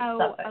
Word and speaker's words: Suffolk: It's spelled Suffolk, but they pronounce it Suffolk.